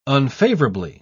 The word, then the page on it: unfavourably: British English